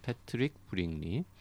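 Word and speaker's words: Patrick Bringley: 나는 메트로폴리탄의 경비원입니다를 읽고 있는데 일단 전 궁금한 게 이게 브링리라고 읽는 게 맞겠죠? 어떤 문서에는 브링글리라고 아. 적혀 있기도 하던데 브링리겠죠. – 브링리가 맞을 네네네. 것 같죠? 네, 패트릭 브링리.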